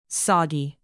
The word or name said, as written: soggy